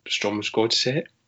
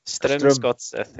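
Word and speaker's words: Strømsgodset: And we've already touched on Strom's Godset. Is that my pronunciation? – Yeah, I can get that one fine. Strom's Godset's not an issue for me. – Strom's Godset. – There you go.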